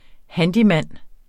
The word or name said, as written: handyman